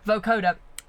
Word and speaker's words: vocoder: Vocoder.